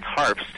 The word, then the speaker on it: Harbst: It's Harbst. It's a German name, and what makes it difficult for a lot of people is there's four consonants in a row.